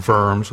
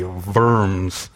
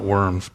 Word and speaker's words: Worms: And if you look on your study sheet, you'll see there's a picture of Martin Luther at the Diet of Worms, which is how you pronounce that, Worms with a V. If you're really German, you would just make it a hard V, Worms. It's not worms, but anyway.